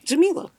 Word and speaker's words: Jamila: So do la, I mean do re mi, and then like the way mi is spelled is in my name. So hopefully that helps people. – So Jamila. – Jamila.